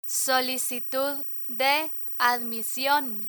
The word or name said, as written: solicitud de admision